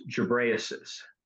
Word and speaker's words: Ghebreyesus: But his name is Tedros Ghebreyesus. His last name is pronounced different ways, but that's one of them.